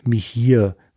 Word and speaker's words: Meeher: Und der zweite Blender ist ein Mann namens Mihir, würde ich das jetzt mal aussprechen, also M-E-E-H-E-R.